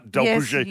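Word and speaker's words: d'Alpuget: Is that, can I say that right? Delpouget. – Yes, you did.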